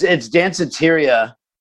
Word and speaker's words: Danceteria: Danceteria? Am I saying that right? – It's Danceteria. And it was the name of a club in New York City in the 80s.